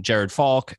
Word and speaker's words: Jared Falk: And I was very intrigued, particularly because, you know, seeing you doing videos and things, and then Jared Falk, and I hope I've got his name pronounced correctly. – Yep. Yeah, you got it.